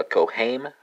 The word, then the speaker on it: correm: This is going to be, in Portuguese, it's going to be a correm, right? It's spelled a C-O-R-R-E-M. The way I remember this is like, it's just co, and then haim is it, the band haim.